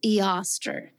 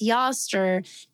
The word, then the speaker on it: Eostre: There is literally one mention of the goddess name Yoster, which is spelled E-O-S-T-R-E.